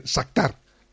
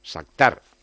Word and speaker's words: Shakhtar: Y, ha, pronunciado evidentemente muy bien Sactar, que nosotros lo hacemos eh, llana, pero es aguda, es Sactar. – Sactar, de Donetsk.